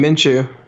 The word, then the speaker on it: Minshew: 4 Rippon, 5 Stick, 6 how do you say that name? Minshew? – Yeah, Minshew.